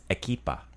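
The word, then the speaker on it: ekipa: The word for team is equipa. – Equipa. – Very similar to Spanish. – There you go. Equipa. – Equipa. – We'd spell it with a K.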